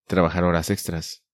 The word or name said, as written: Trabajar horas extras